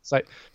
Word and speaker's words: Saito: Saito.